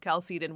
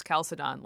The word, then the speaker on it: Chalcedon: So the two kind of poles that were battling it out in what came to be the Council of Chalcedon, which you can also pronounce Chalcedon, like you can say Augustine or Augustine, right?